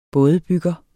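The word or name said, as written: BÅDEBYGGER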